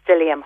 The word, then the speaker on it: psyllium: And then the fibre that you do want to put in is the psyllium husk.